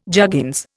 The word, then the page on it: juggins: Indian